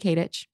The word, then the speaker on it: Katich: K-A-T-I-C-H. – Yeah. – Do people mispronounce it a lot? – Yeah, people say Katish a lot, which makes sense. I feel like it looks that way.